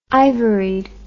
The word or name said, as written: ivoried